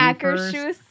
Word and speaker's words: Akershus: And then moving over to Epcot, there is a restaurant in the Norway Pavilion called Akerhus. – Nope. – Nope. – We literally just listened to the pronunciation Akershus.